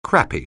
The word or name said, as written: crappy